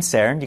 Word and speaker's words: CERN: C E R N, CERN.